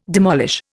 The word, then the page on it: demolish: Indian